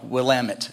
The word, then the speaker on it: Willamette: I pronounced it wrong like four different ways. And finally, Chris got me ironed out. I know how to pronounce it now, Willamette,